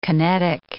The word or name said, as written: kinetic